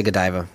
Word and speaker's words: Godiva: C-A-R-T-I-E-R. – Cartier. – That's correct. – Oh. – Chocolates. G-O-D-I-V-A. – See, Godiva. I guess I've always said that wrong. I say Godiva, but I say Godiva. That's what I say. – Yeah, that's what we all say. Yeah. It's Godiva. – Godiva. – Godiva. I guess Diva. Yeah, Diva. Godiva D-I-V-A.